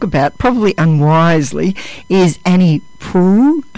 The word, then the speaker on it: Proulx: Sounds like I'm one of those girls off, that comedy show, Prue and Trudy. But we looked up how to pronounce it, and that's right. I always used to say it Proulx.